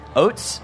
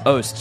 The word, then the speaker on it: Oast: Oats? No, it's Oast.